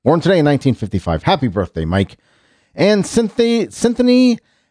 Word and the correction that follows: Erivo: Erivo, right? Am I saying her name right? Erivo? Erivo, Cynthia Erivo?